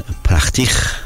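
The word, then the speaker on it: prettig: یک نمونه دیگرش واژه زیبا هستش، کلمه زیبا به راحتی pretty, nice, beautiful، بهش میگن پرختیخ. خیر زیاد میگن، گفتم که، و با چه خیر غلیظی هم تلفظش میکنن. حرف جی در خطوط در زبان هلندی خ تلفظ می شود و نه گاف یا جی.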